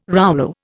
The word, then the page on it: rouleau: Indian